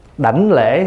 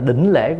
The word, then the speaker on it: đảnh lễ: Đảnh lễ hay là đỉnh lễ cũng được.